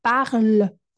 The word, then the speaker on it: parle: Mas eu esse é, no final não falo, eu falo parle, como se fosse até o L. Je parle. Je parle français. Então, a visualização desse E e entender que esse E nessa sílaba, sem acento, ele não existe, é muito difícil. Ele é, ele é inexistente, ele é mudo.